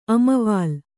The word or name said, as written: ಅಮವಾಲು